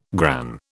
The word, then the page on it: gran: British English